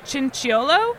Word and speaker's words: Chinchiolo: I'm really excited to chat with my new friend, Adam Chinchiolo. Did I say that right? – That's right.